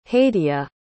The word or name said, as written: hadia